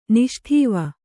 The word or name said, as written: ನಿಷ್ಠೇವ